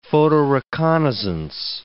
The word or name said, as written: photoreconnaissance